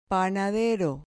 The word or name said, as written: panadero